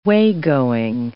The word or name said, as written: waygoing